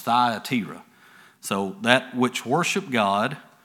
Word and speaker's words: Thyatira: 16 and 14, and a certain woman named Lydia, a seller of purple of the city of Thyatira. Actually, I pronounced that wrong. That's Thyatira is how that's pronounced.